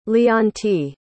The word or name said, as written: leontiy